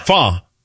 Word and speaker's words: Pho: Pad C-U. – No. – Fa? – Fa. – Oh. – Yeah. P-H-O.